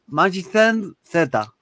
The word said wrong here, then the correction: Mazinger: los del robot. – Ah, el Magin Z, Z. – Mazinger.